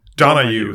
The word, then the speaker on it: Donahue: Donahue, vad säger man? – Donahue tror jag man uttalar det. – Ja, precis.